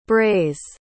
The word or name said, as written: brays